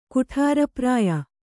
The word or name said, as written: ಕುಠಾರಪ್ರಾಯ